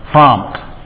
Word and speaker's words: prompt: If you listen carefully to how kids are pronouncing them, they're often not getting the T in there. Their glass is empty. You know, they want to get there promptly with no T in it.